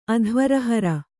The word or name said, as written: ಅಧ್ವರಹರ